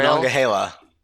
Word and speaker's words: Monongahela: Is it Monongahela Trail? Monongahela.